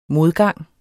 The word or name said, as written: modgang